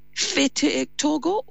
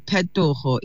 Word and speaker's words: Petogo: So it's Fete Togo or Pete? How do you say it? I don't want to get it wrong. – It's a hard P. Petogo. It means change.